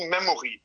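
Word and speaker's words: Memori: Oder spricht man die Memory aus? – Ja, machen ein paar Leute, aber wir sagen dazu Memory, weil es ist doch schon etwas angelehnt an dieses Kartenlegespiel Memory, ja.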